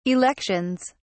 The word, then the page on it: elections: British English